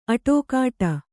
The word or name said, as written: ಅಡಿಗುಟ್ಟು